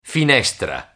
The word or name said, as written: finestra